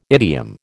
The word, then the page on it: idiom: American English